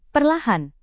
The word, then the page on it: perlahan: Indonesian